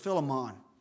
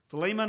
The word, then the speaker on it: Philemon: And I will tell you, if you use a Bible pronunciation, some people pronounce this Philemon. Either one of them is correct when you go to a Bible pronunciation app. Philemon or Philemon.